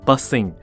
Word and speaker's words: bussin: 美国大众的圈子里面呢，你也时不时会听到说，Oh, that's buzzing。就代表很酷、很赞的意思啦。好，buzzing，那我现在拼一下，b u s s i n，b u s s i n。那这个字词的声音呢，读起来就跟 b u s s i n g 叫做搭公车啊，搭 bus 叫做 buzzing，是一模一样的发音哦。